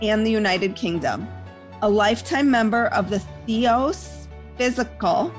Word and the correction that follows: Theosophical: did I say that wrong? – Theosophical. – Theosophical Society.